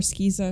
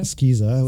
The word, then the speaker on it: SCISA: I uh, prefer uh, SCISA. Skiza. Skiza. We, we, have uh, we have debates about how to pronounce yeah, acronyms. We call it Skiza.